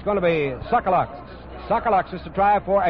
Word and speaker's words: Tsagalakis: He spells his name T-S-A-G-A-L-A-K-I-S and pronounces it Sokolaks.